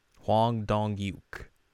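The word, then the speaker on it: Hwang Dong-hyuk: All You're gonna have to tell me how to pronounce the creator of the show's name. – right, I gotta find that. – It's down in production development. – Production development. Hwan Dong Yuk. – Huang Dong-yuk. We can go with that.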